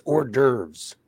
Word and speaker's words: hors d'oeuvres: You mean hors d'oeuvres? – Oh, is that how you say it? I always thought it was. Because it's spelled H-O-R-D. – Hors d'oeuvres. – Hortivores, okay. – It's hors d'oeuvres. – Hors d'oeuvres. – You don't pronounce the H.